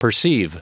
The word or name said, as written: perceive